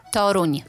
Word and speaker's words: Toruń: Toruń.